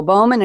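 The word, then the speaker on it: Bohman: And now let's go to Joe Bowman. Anybody wants to spell his last name, it's B-O-H-M-A-N. We've got a silent H in there.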